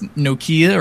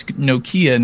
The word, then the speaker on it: Nokia: Like the Kia cars. Nokia.